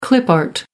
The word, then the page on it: clip art: American English